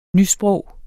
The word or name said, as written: NYSPROG